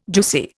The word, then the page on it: juicy: Indian